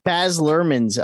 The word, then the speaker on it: Baz Luhrmann: Baz Luhrmann's Elvis.